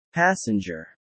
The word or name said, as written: passenger